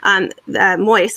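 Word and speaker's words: Moïse: Moise, that's how you say his name, whose government declared this month that all transactions now must be done in Haiti's national currency, the gourd.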